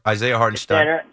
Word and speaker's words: Isaiah Hartenstein: Yeah, listen, I'm speaking of Willis Reed, I mean, if it wasn't because Isaiah Hartenstein, did I say that right? – Yeah, Isaiah Hartenstein.